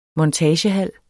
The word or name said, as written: montagehal